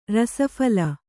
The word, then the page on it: ರಸಫಲ: Kannada